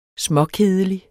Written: småkedelig

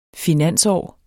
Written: finansår

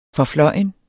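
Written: FORFLØJNE

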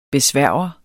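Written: BESVÆRGER

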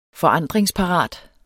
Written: forandringsparat

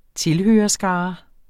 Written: tilhørerskare

